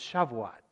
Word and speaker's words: Shavuot: You know, this feast of Pentecost comes from a Jewish feast of Shavuot. I asked our resident Jewish parishioner how I pronounced it, and she didn't know, so I went and I looked it up. It's Shavuot.